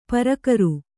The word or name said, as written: ಪ್ರಾಕಾರ